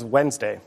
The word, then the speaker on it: Wednesday: Shakespeare's first folio spells this day of the week as it's said, without the first D. – What is Wednesday? – Yes.